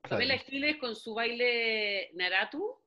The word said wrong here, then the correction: Naruto: no, lo dije mal. – No, sí, Naruto, Naruto.